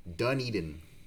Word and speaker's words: Dunedin: And in fact, it's pronounced Dunedin. As in, like, I'm all Dunedin.